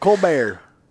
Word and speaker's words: Colbert: There ain't no T there, buddy. Sound it out. Colbert.